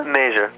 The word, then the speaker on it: Mazur: Is it Mazur? – Major. – Major. Yeah. I knew I would pronounce it wrong.